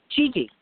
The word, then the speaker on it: Gigi: Gigi. – Gigi? – Yes. – How do you spell that? – G-I-G-I. – G-I-G-I.